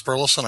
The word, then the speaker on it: Burleson: Hi, I'm Thomas Burleson.